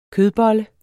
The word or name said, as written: kødbolle